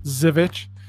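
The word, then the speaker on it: Żywiec: It is Z-Y-W-I-E-C. And the people that are from Poland that I know tell me it's pronounced Zivich.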